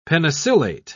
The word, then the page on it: penicillate: British English